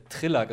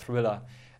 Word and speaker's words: Thriller: Also eine Sache, die ich wirklich ewig lang falsch gesagt habe, ich habe hab immer äh, Triller gesagt statt Thriller. Äh, selbst als ich Englisch in der Schule hatte, dachte ich immer, es heißt Triller und habe alle ausgelacht, die Thriller gesagt haben,